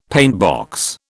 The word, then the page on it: paintbox: British English